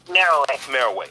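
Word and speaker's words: Meroë: Now, when we look at, is the correct pronunciation, Meroe? – Meroe. – Okay, M-E-R-O-E, Meroe.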